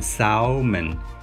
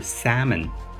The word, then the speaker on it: salmon: 我们来讲讲怎么发哈，这里呢 a l 呢，它发的不是 l，而是一个简单的梅花音 a a 就好了，所以你不能发成 salmon，应该是 salmon salmon 就够了，不是 salmon，也不是 salmon。 The correct pronunciation is salmon, salmon。